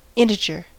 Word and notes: integer: American English

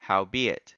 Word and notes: howbeit: American English

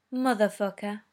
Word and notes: motherfucker: British English

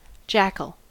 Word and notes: jackal: American English

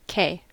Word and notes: kay: American English

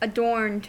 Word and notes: adorned: American English

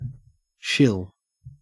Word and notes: shill: Australian English

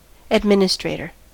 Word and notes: administrator: American English

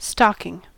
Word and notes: stocking: American English

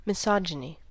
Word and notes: misogyny: American English

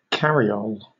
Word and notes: cariole: British English

